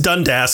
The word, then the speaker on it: Dundas: But no, I looked it up and it is pronounced Dundas.